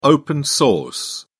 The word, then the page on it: open-source: British English